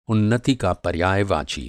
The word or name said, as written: उन्नति